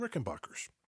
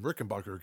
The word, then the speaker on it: Rickenbacker: Until everybody just starts calling them Rickenbacker guitars. – Right. And even the pronunciation is weird because it's Bacher, but it's spelled Backer.